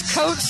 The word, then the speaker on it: Coates: My name is Delaney, D-E-L-A-N-E-Y. – What's your last name? – My name is Coates, C-O-A-T-E-S.